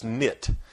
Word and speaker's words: Neith: And that Nit is spelled N-E-I-T-H. It looks like it would be Neith, but it's pronounced Nit.